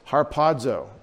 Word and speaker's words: harpazo: That is harpazo. H-A-R-P-A-Z-O is the lexical form.